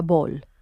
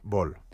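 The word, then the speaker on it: Böhl: Oye, confirmo que Böll se pronuncia Böll, ¿vale? – Böll.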